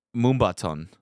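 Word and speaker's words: Moombahton: Moombaton. – Okay. – Did I even say that correctly? – A little bit. Yeah, yeah. You know, you got it right, actually.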